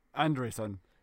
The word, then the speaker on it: Andresons: If you want to say my full name and surname, Chris, you say Kristaps almost correctly, but Andresons is pronounced like and Ray Son together in the single word. Andresson.